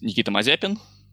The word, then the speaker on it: Mazepin: I mean, again, Mazepin's fine, but yes, it's Nikita Mazepin, but Mazepin's fine. I don't think he minds very much.